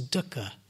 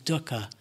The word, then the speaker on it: dukkha: and the word is dukkha. It's spelled D U K K H A.